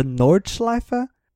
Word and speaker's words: Nordschleife: So the I'm butchering this its German name, but the Nordschleife. – No, that sounds right to me.